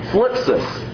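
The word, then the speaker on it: thlipsis (θλῖψις): Now, I want you to note the word for tribulation here in the Greek, it's not easy to say. It's thlipsis. Thlipsis. T-H-L-I-P-S-I-S. You really got to be Greek to say it correctly.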